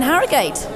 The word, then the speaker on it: Harrogate: This week we're in Ourgate. – Where are we? – We are in Harrogate.